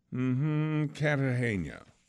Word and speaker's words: Cartagena: It is Cartagena.